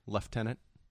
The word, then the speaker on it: lieutenant: Lieutenant with a V. – Lieutenant? – It's pronounced like it has a V in it.